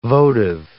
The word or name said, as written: votive